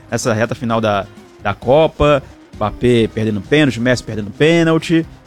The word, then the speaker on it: Mbappé: No francês é Mbappé. – Mbappé. – O M não se pronuncia.